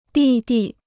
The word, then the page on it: 弟弟: dì di